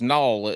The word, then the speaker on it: Nall: Now, I don't know if that's how you pronounce your name, but me being country, N-A-L-L is Nall. It needs to have a W in there somewhere.